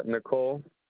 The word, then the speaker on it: Nicole: It's going to be under Nicole De La Luz. – Can you spell that for me, please? – N-I-C-O-L-E.